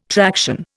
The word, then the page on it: traction: Indian